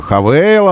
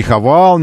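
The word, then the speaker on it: Haval: Хавейл она произносит. Не Хавал никакой, а именно Хавейл.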